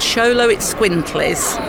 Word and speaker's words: Xoloitzcuintle: They're called Xoloitzcuintles. – But it's spelled X-O-L-O-I-T-Z and Quintly on the end, but you've done it phonetically, that Xoloitzcuintle. – That's right. – Right, yes. – I've got it right, fantastic.